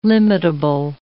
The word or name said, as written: limitable